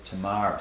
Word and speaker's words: Tamar: Some people say Tamar, but I think most Jewish people prefer to pronounce it Tamar, so I'm going to call her Tamar this evening.